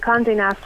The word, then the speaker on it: Condé Nast: Condé Nast. Am I saying that correctly? – Yeah, that is a very exciting one. Condé Nast have released the top 5 restaurants on the Ring of Kerry.